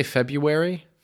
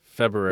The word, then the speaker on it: February: Don't we say February? – February. – It's like a Michigan thing. I don't pronounce the R.